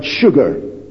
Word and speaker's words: sugar: only one word in the English language which was spelt S but pronounced S-H, and that was the word sugar.